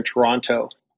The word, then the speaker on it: Toronto: Toronto. And then we also, just for the listeners, I pronounced Toronto completely incorrectly for someone who lives in downtown Toronto. We just get rid of the last T.